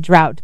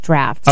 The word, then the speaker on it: draught: So here, D-R-A-U-G-H-T would be drought, but there it's pronounced draft.